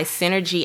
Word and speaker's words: SynRG: This episode of Inspired Conversations is actually sponsored by Synergy App. That's spelled S-Y-N-R-G.